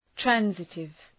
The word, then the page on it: transitive: ‘trænsıtıv